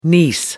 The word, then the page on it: niece: American English